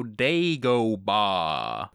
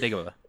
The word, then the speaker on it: Dagobah: The answer to how you are supposed to pronounce Dagobah is you go on it like you are Foghorn Leghorn. You go Dagobah. Not Dagobah.